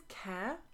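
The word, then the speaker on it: Kjær: I think it's Kjaer. – Oh, God, I've been pronouncing the J all the time.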